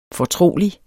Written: fortrolig

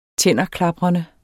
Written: tænderklaprende

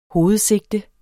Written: hovedsigte

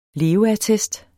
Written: leveattest

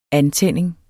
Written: antænding

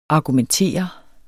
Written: argumentere

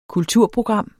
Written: KULTURPROGRAM

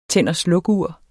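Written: TÆND

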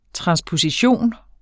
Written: transposition

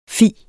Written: fi

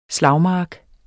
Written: SLAGMARKERNES

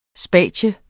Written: SPATIUMMERNES

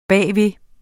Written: bagved

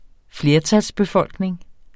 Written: flertalsbefolkning

